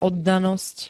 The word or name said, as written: oddanosť